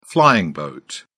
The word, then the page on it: flying boat: British English